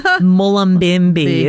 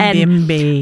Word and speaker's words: Mullumbimby: I think it's Mullumbimby is how it's pronounced. – Mullumbimby.